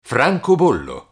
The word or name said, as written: francobollo